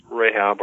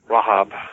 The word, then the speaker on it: Rahab: Her name is Rahab or Rahab is the Jewish pronunciation,